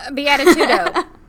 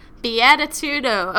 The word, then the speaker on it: beatitudo: Beatitudo. – Beatitudo. Okay. Beatitudo. Thank you, Aristotle, for the funny words. Beatitudo. The happiness from doing good for others and making the world a better place. – Yeah. Beatitudo.